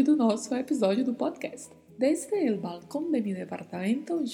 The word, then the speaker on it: desde: Falamos desde, desde, é o E mais fechado e o S com um som bem marcado de S mesmo.